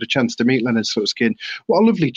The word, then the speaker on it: Slutsky: What was the crack with Leonard, was it Slutsky? Is that the correct pronunciation? – Yeah, Leonard Slutsky.